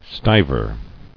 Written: Stiver

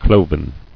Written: Cloven

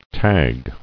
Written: Tagging

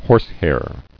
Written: Horsehair

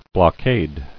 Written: Blockader

